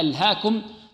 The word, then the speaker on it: ألهاكم: ألهاكم عندنا هنا إقلاب ما هو الإقلاب يعني ياء تنطق ألف وترسم ماذا ياء